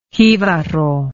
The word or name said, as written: jibaro